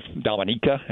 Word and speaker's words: Dominica: Nobody's ever heard of Dominica, is how it's pronounced.